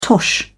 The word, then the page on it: tosh: British English